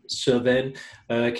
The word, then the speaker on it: Cévennes: Cévennes, keeping the phallic images up for anyone who's watching this video.